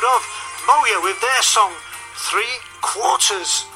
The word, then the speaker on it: Moea: M-O-E-A, pronounced Moya.